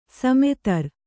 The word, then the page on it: ಸಮುದ್ರ: samudra; Kannada